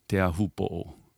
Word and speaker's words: Teahupo'o: And they say Teahupo, which is absolutely correct. But that's not how it's pronounced in Tahiti. Like nobody really pronounces it that way except for maybe old people.